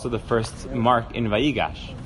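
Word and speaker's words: vayigash (ויגש): So vayigash, the emphasis is in the middle.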